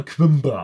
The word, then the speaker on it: Coimbra: Mir wurde erklärt, das spricht man Quimbra aus.